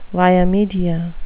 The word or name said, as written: via media